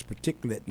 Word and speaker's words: particulate: And it has to deal with the particulate matter. I did this at one place, and they thought I was mispronouncing particular. But no, it's particulate matter.